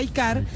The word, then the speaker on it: iCaur: se escribe iCaur, pero se dice iCar, para que busquen y puedan ver las prestaciones de ambas camionetas en 100% eléctrico o en híbrida de rango extendido.